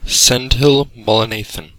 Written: Sendhil Mullainathan

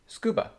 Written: Scuba

